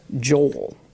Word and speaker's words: Joel: But actually, though few people pronounce it this way, my name is a two-syllable name. It's Joel.